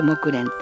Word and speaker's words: 木蘭: 木蓮っていうのはね、先週も言いましたように木の蘭の花の蘭と書いて、木蘭と書いて木蓮って呼んだり、それから蓮の花の蓮って書いて木に蓮の花で木蓮って呼んだり、そんな感じもするんですけれどもね、そんな風に呼んだりもするようです、ね、面白いですよね。